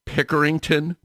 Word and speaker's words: Pickerington: it's Pickerington Public Library.